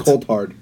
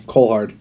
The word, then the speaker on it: Coulthard: It's Colt. – It's Colt Hard. – Colt Hard? – Colt Hard. Like, I think it's spelled C-O-U-L-T-H-A-R-D. Like Colt Hard. – What did I say? – Cole Hard.